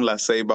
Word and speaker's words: La Ceiba: And no worries, I'm originally from La Ceiba, Honduras.